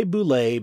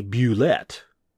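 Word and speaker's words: bulette: And I say boule because that's how Tim Kask, the man who invented the boule, pronounces it. We used to call it a bullet. Some people call it a bullet. Well, boule is Tim Kask's preferred pronunciation. I think we should honor the creator of said monster and go with his pronunciation.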